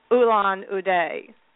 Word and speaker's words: Ulaanbaatar: You're riding the train for a couple of days before the first stop at Ulaanbaatar.